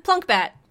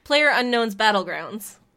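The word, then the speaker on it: PlayerUnknown's Battlegrounds: Plunkbat. – Player Unknown, what is it? Player Unknown Ground? – Player Unknown's Battlegrounds.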